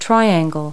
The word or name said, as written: triangle